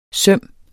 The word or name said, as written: søm